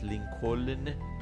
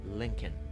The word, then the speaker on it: Lincoln: لینکن لینکن پس لینکلن نیست لینکن کن کلن نیست